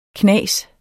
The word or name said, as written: knas